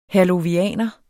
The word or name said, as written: herlovianer